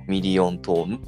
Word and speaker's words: 百万音色: で、 100 万音色ってして、ミリオントーンって読むやろ。